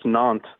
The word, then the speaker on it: Nantes: I'm rather impressed by the way you pronounce Nantes. – Did I do okay? – Yeah, it's good. It's good. A lot of people actually pronounce the S, and you did well.